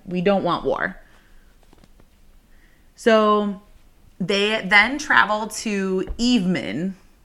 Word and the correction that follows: Evaemon: Did I say that right? – Uh no, it's Evaemon.